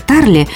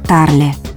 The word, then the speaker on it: Тарле: Если запомнить этот стишок, то уже никогда не ошибешься в ударении, произнося фамилию замечательного русского ученого академика Тарли. А то многие путают.